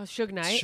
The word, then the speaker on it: Suge Knight: Oh, Suge Knight.